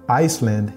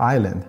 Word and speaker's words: island: It was my turn to read it in class, and I pronounced the word Iceland instead of island.